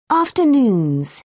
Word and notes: afternoons: British English

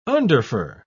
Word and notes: underfur: British English

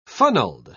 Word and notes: funneled: British English

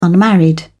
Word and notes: unmarried: British English

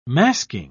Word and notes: masking: British English

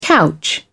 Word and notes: couch: British English

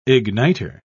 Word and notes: ignitor: British English

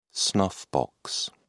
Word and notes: snuffbox: British English

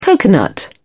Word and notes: coconut: British English

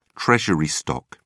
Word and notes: treasury stock: British English

